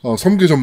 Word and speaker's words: 섬계: 섬계 전문가. – 네. 섬계 전문가죠? 예. 성계가 아니라 섬계입니다. 네. – 그렇죠. 성계가 아니고요. 섬계. – 네. 발음상 네. 주의해 주시기 바랍니다.